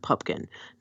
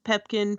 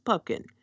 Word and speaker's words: Pupkin: Rather, it refers to the main character of Rupert Pupkin. Not Pumpkin, Pepkin, Pomplamoose, it's Pupkin.